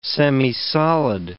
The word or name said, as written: semisolid